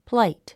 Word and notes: plight: pláit; プライト

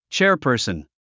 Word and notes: chairperson: tʃέə r pə̀ː r sən; チェアパーソン

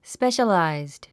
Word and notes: specialized: spéʃəlàizd; スペシャラァイズド

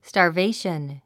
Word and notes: starvation: stɑː r véiʃən; スタァベェション

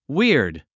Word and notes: weird: wíərd; ウィアード

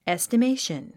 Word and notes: estimation: èstəméiʃən; エステメイション

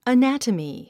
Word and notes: anatomy: ənǽtəmi; アナタミィ

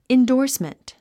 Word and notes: endorsement: indɔ́ːrsmənt; インドースメント

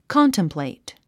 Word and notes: contemplate: kάntəmplèit; カンタムプレイト

